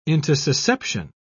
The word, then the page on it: intussusception: British English